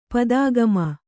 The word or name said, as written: ಪದಾಗಮ